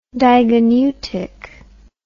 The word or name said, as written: digoneutic